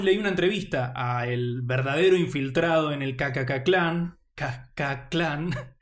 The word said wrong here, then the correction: Ku Klux Klan: Digamos Ku Klux Klan. – Sí, pero también es difícil de pronunciar porque hay una L ahí, sí, entre ellos. Ku Klux Klan sería.